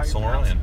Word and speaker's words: Silmarillion: Yeah, Silmarillion.